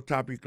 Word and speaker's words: topic: Alors, mot topic là, qui signifie sujet, ça veut dire accent tonique là tombé sous premier syllabe là.